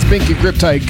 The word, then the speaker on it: Binky Griptite: So say my name with respect. It's Binky Griptite giving you the boogie down on the Cosmo.